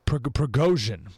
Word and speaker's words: Prigozhin: I've been practicing all morning. Prigozhin, Prigozhin. That's it.